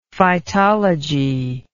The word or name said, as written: phytology